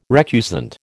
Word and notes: recusant: American English

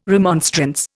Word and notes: remonstrance: Indian

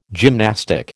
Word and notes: gymnastic: American English

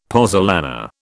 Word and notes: pozzolana: British English

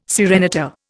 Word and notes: serenata: Indian

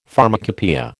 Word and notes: pharmacopoeia: American English

